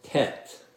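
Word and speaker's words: Tet (ט): Tet. It kind of rhymes with het. It's not really like our T, but it kind of is a little bit.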